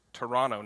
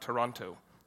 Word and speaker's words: Toronto: It's about 6 hours straight north of Toronto, which is Toronto, not Toronto. They'll tell you you're saying it wrong if you say Toronto.